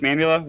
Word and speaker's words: Mamula: Coach Mamula, welcome to the podcast.